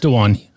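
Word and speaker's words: Dewan: Of, of the major players for us in the summer, which is uh, Chris Boucher, uh, Terrence Davis, um, Hernandez, and uh, is that pronounced Duan? Duan Hernandez? No, it's Duan.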